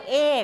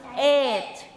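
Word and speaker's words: eight: เราจะออกเสียงว่าเอกซึ่งเหมือนกับคำว่าไข่ซึ่งไม่ใช่ต้องออกเสียงว่าเอทเอทไม่ใช่เสียงกอไก่นะคะ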